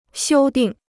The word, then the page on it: 修订: xiū dìng